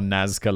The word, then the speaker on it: Nazca: I'm fairly sure Nazca Lines is pronounced like that, but like Jamana, I'm honestly not even sure of the pronunciation of desertic, and that is not a place.